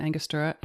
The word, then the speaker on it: Angostura: We're using Angostura, and I said it right this time for once.